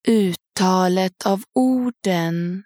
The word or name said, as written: uttalet av orden